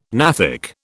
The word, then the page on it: gnathic: British English